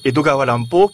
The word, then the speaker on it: Edogawa Rampo: En japonais, se prononce Edogawa Rampo, qui est en fait la transcription phonétique d'Edgar Allan Poe.